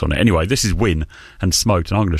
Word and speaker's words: Wynne: It's called Win, which is spelled W-Y-N-N-E.